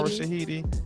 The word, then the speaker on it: Shahidi: Not only did our girl Yara Shahida, did I say her name right? Yara Shahidi.